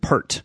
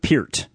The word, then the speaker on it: Peart: The drummer for the legendary, I'll call him a prog rock band, Rush, Neil Peart, who sometimes people pronounce it Pert. In fact, when I was younger, we used to call him Neil Peart. But I've seen a couple of video interviews with him recently where they pronounced it Peart. So I'm figuring, you know, he would have either, you know, told them how to pronounce it or maybe corrected them or whatever. So I'm going with Peart,